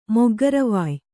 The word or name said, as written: ಮೊಗ್ಗರವಾಯ್